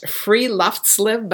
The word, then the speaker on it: friluftsliv: And they say it's pronounced friluftsliv, but I don't know where the accent would be, but friluftsliv.